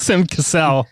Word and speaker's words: Sam Cassell: And it's just the Sam Castle. – Sam Castle. Like, Sam Cassell.